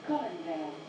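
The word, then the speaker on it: Colindale: The next station is Collindale. – So I am pronouncing it right, that's good.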